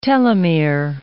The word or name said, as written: telomere